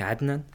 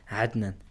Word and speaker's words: Hadnan: Adnan is een uh, eh, 20-jarige student. Uh, kan je wel even corrigeren, mijn naam is eigenlijk Hadnan, op zijn Marokkaans uitgesproken. Hadnan.